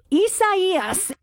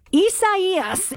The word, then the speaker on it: Isaias: and I think it's pronounced Isaias. Is that right? – Isaias. – No, I didn't get it right. What? Say it again for me. – Isaias. – All right. It's spelled Isaias.